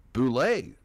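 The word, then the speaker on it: Boule: It's Bivens, and I also say I've spoken to counsel for the respondent in Egbert versus Boole, and they've informed me that it is pronounced Boole. – Boole.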